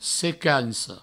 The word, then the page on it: secanca: secanco